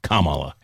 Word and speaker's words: Kamala: Or it's supposed to be pronounced like a comma, Kamala Harris.